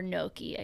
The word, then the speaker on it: gnocchi: Pesto gnocchi. Or gnocchi, I guess is how they say it.